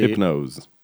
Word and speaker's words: Hypnose: Hypnose. Hypnose, that would be the French pronunciation. A pronunciation française.